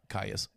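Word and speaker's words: Kyuss: Is it Caius or Caius? – It's Caius. – It's Caius. Okay. I've been saying that wrong for roughly a decade.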